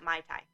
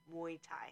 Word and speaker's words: Muay Thai: Yeah, Muay Thai, not Mai Thai, Muay Thai.